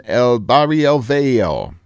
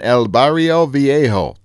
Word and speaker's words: El Barrio Viejo: This year's show moves outdoors for a festival in El Barrio Viejo. Pronounce it correctly. Let me run that by you again. Festival in El Barrio Viejo. There, I got it right, yeah.